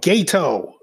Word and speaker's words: Gedo: Yeah, G-E-D-O. It's pronounced. – His name is Jato. – That's not his real name. That's his wrestling name. – Oh, I'm about to say, that's his real name, Jato? – No, Gato.